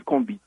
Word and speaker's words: Combit: Est-ce que je prononce bien? – Ouais, un morceau de Franz Cassius qui s'appelle Combit.